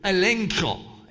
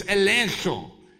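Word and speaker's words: elenko (ἐλέγχω): So this is the word elenko. And so you need to actually have a little chich sound at the end of it, but it's elenko, elenko.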